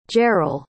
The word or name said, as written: jaryl